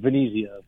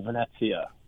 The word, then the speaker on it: Venezia: It's Venezia, but, you know, real Italian, say Venezia. – Venezia. – Like basically Venice, like Venice, but Venezia. That's the Italian way to say Venice.